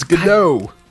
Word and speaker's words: Gadot: It's Gadot.